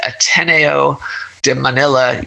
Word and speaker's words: Ateneo de Manila: And then she got her JD from the Ateneo de Manila University.